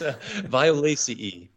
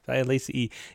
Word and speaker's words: Violaceae: It's Violaceae. – Violaceae.